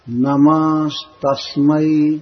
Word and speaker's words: नमस्तस्मै: जैसे है इसमें नमः, नमः शब्द है, नमस्तस्मै त पर ज्यादा जोर दिया जाएगा और म पर भी। नमस्तस्मै त के बाद स है आधा, इसलिए त थोड़ा मोटा उच्चारण होगा, दीर्घ उच्चारण होगा।